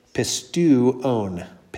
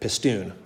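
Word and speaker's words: pistuon (πιστεύων): So it's pistuon, pistuon. Because the epsilon and the upsilon form a diphthong. Mm-hmm. But then the omega has to be pronounced by itself because that's not a diphthong. So you have two vowel sounds there.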